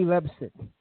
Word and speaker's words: Leipzig: Paul Mitchell from RP Perceps, I think it's how you pronounce it. Let me just double check the pronunciation on this. RP Leipzig in Germany.